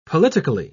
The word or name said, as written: politically